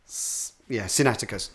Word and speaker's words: Sinaiticus: The British Library, there's the Codex Sinaiticus. I was just looking up to make sure I was pronouncing it correctly. Sinaiticus.